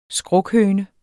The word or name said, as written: skrukhøne